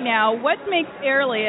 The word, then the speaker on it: Airlie: I am the senior sales manager at this beautiful, beautiful property in Warrenton, Virginia, called Airlie. It's spelled A-I-R-L-I-E, just like the air you breathe.